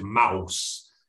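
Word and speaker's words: Maus: Even though it's M-A-U-S, they're a German make. You'd pronounce it mouse.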